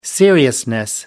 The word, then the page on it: seriousness: American English